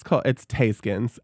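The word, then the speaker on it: Theyskens: I guess it's Thaiskins. I put in parentheses how you pronounce it.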